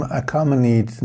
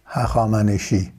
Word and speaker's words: Hakhamaneshi: First of all, the name Achaemenid nowadays being said is wrong. Persians pronounce it as Hachamaneshi.